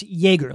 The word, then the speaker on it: Joerger: J-O-E-R-G-E-R. Pronounce Jaeger, like yay. Ger, or like a Jaeger bomb.